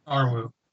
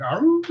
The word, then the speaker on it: Arwu: Yeah, and his, what's the Awu? Am I saying that right? – Arwu. – Arwu? – It's like the noise a werewolf makes. Like, Arwu.